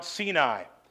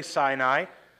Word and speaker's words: Sinai: It's okay to say Sinai, but in Hebrew it would be Sinai.